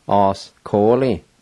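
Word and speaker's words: askoli: Number five is head, os-ko-li, os-ko-li, os-ko-li.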